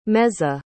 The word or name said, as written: meza